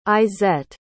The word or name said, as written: izett